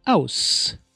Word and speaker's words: aus: But at the end of the word, S is pronounced as seal, like in aus or S.